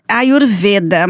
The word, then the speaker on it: Ayurveda: É assim que se pronuncia? – Ayurveda, mas Ayurveda. Não é curso, é um workshop.